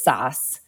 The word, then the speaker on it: Sass: Um, so the um, my f- father came from Germany. The correct pronunciation is Sass.